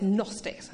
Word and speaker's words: Gnostics: Okay, it's spelled Gnostics, but it's Gnostics.